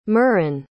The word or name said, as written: murron